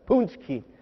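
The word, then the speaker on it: pączki: Mardi Gras, pancakes, punchki. That's how you say it, by the way. As the grandson of a 100% Polish man, I can tell you that. It's punchki.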